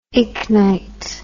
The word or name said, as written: ichnite